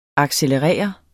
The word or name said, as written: accelerere